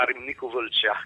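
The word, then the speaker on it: Râmnicu Vâlcea: che non è limitato sicuramente a Ramniciu Valcea, come si pronuncia bene alla romena,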